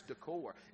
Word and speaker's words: decor: Brother Greg texted me later on, and he said, have you heard anything back from Banks, Flooring, and Decker? I said, what are you talking about, man? And I didn't realize I had said Decker. It's decor.